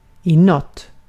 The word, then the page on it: toward: American English; [tə.ˈwɔɹd]